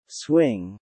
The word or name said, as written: swing